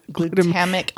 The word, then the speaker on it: glutamic: How about ingredients that may contain or produce processed free glutama- glutamatic Gl- glutamic glutamic acid.